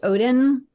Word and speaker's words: Odden: So, first of all, just FYI, my last name is pronounced Odin, kind of like the like the Norse god who ripped an eyeball out of his head in exchange for power.